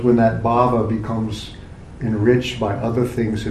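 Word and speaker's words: bhāva: The word bhava in Sanskrit with a short A just means existence, but with a long A, bhava is a state of being or a state of mind, a state of consciousness or an emotion.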